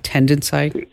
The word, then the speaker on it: Tendenci: It's TENDENCI, like tendency. Yeah, tendency, like TENDENCI.